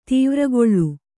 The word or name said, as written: ದ್ವಾರಗೊಲ್ಲ